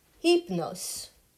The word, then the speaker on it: Hypnos: The Greek god of sleep is Hypnos. It is spelled H-Y-P-N-O-S.